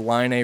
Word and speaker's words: Leine: Now, one day, these two neighborhood boys, were playing on the bank of the Line River. I think that's how you say it. It's spelled L-E-I-N-E, but I think you say Line.